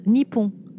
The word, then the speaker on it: Nippon: Et en japonais, Japon se prononce Nippon.